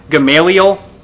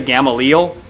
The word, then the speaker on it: Gamaliel: We get this man, Gamaliel. So I realize I've talked to people about this. I say his name wrong. I usually say Gamaliel. I want to say Gamaliel, because I think that's actually correct.